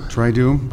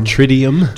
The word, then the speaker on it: Triduum: Is this pronounced the Triduum? – Triduum by some. – Or Tridium? – Tridium. – No, it's a weird word. T-R-I-D-U-U-M.